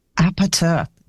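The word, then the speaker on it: APT.: Apate. That's the Korean pronunciation of the name? – Yeah. – Awesome. Okay, because it looks like APT or apartment, depending on how you're reading it.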